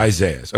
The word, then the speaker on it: Isaias: Isaias, is that the right way to say this? We got a state of emergency in the state of Florida for Isaias. So that's right, Josh, you said? – Yeah.